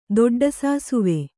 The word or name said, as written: ದೊಡ್ಡಸಾಸುವೆ